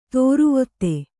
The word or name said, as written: ದುರ್ವಾದ